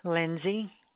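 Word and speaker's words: Linzy: Lenora Lindsay.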